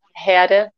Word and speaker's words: Herde: And um, just to introduce myself quickly, my name is Jana Herde, a very German last name, so you you guys don't have to learn how to pronounce it.